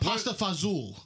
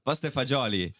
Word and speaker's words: pasta fagioli: What about pasta fazool? – Pasta fagioli.